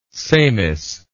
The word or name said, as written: semis